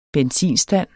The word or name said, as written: benzinstand